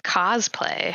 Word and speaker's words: cosplay: It's actually pronounced cosplay. – Cosplay, okay. – Like thank costume you. – Play. – Costume play, yeah.